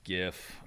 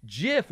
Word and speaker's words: GIF: But I think it is pronounced GIF. – Former CompuServe engineer, GIF creator Steve Wilhite told the New York Times that it should be pronounced Jif with a soft G.